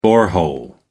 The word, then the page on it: borehole: American English